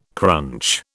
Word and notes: crunch: British English

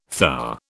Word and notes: thar: British English

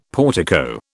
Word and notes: portico: British English

